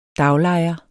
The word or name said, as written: daglejer